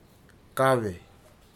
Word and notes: kave: futuna aniwa

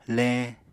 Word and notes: leñ: Apatani

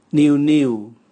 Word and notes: niu niu: Raga

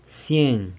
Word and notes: sieng: hundred

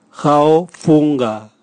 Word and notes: gaofunga: Raga

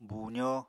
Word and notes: buuzó: apatani

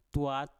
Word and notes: tuat: mpur

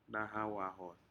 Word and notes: nahau ahod: Aneityum

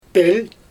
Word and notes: pölJ: Xyzyl

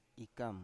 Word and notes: ikam: Biak

